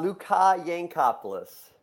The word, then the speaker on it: Luka Yancopoulos: Luka Yancopoulos.